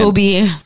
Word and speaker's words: Kobe: Kobe.